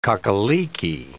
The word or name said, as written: cock-a-leekie